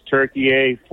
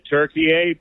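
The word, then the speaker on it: Türkiye: The Turks have always called it Turkey A, but in English, the pronunciation was like the bird.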